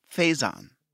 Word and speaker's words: Faison: If you do win, it's pronounced Faison. – Faison. Okay. Is it Faison? No, motherfucker. Faison. Faison, Donald Faison.